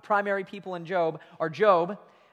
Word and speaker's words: Job: In fact, not just that, but the very first book of the Bible ever written was a book named Job, and it's spelled like Job, but it's pronounced Job, and I don't get it, but that's just kind of the way it is.